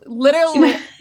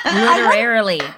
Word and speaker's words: Literarily: Sorry, literally, Literarily.